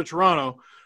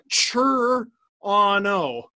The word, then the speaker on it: Toronto: You know, we're very specific with how Torontonians are supposed to refer to Toronto, and it's by basically pronouncing it completely wrong. It's supposed to be like Chur-on-o. – Mm-hmm. – Calling it Toronto is a sin.